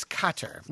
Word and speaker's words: Qatar: Number one is Qatar, which I pronounce Qatar, which is wrong. Q-U-A-T-A-R. Q-A-T-A-R.